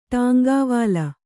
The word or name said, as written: ಟಾಂಗಾವಾಲ